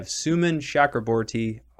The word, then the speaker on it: Suman Chakraborty: I have Suman Chakraborty on the show, and I think I said it right.